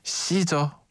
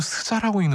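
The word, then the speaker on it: SZA: 태연의 INVU, 시저, 아니면 또 스자라고 읽는 분도 계시고, 킬빌, 헤이즈의 해프누연, 이렇게 세 곡이었습니다.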